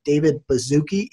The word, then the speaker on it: David Baszucki: Roblox was actually started all the way back in 1989 when the 2 founders, David Baszucki, Ian, am I saying that right? – That's correct.